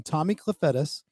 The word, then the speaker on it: Kefetis: and we did a little bit of, with another fellow Greek, Tommy Kefetis, who I can never to this day pronounce his name to his standard, but I think I'm doing a pretty good one. Tommy Kefetis. – Yeah, that's it. Correct.